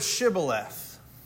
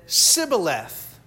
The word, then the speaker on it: Shibboleth: When he said, "No," they said, "Then say the word Shibboleth." And he said, "Sibboleth," for he could not pronounce it right. Oh, they couldn't say the sh. In their language, they didn't have the sh sound.